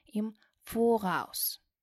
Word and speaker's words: im Voraus: Обратите внимание, что ударение падает на первый слог в слове им voraus.